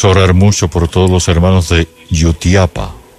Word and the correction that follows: Jutiapa: Es así como se pronuncia, ¿verdad? Yutiapa, para que... Jutiapa, ya.